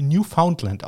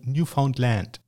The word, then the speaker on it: Newfoundland: Und ähm, die wird im Englischen nicht äh, Newfoundland ausgesprochen, sondern Newfoundland. Ja? Also die Betonung auf, auf Land, also Neufundland, nicht Neufundland, wie wir das äh, sagen.